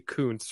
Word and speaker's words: Kuntz: It's Rusty Kuntz, for the record, is how it's pronounced, okay? But it is spelled in a way that would lead you to believe that, yes, it is pronounced as that, but it is Rusty Kuntz.